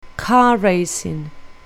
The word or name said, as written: car racing